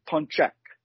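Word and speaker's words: pączek: It's P-A-C-Z-K-I, and it's pronounced punchki, which is the plural. The singular would be punchek.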